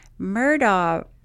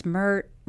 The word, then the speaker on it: Murdaugh: So their name is Murdaugh. Murdaugh. But until moments ago, until I watched it, I thought it was Murdaugh. But then some people do seem to pronounce it Murdaugh. But then when you hear Alex Murdaugh, he mm-hmm. pronounces it Murdaugh.